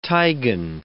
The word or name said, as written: tigon